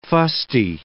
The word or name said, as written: fusty